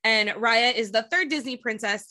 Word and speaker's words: Raya: So Raya's sword was did I say it wrong again? – Raya. – Raya.